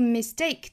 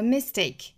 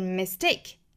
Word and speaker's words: mistake: 这个 mistake 这个单词重读是在第二个音节，不要读成了 mistake，这是错误的，应该是 mistake，哒哒 mistake make mistakes。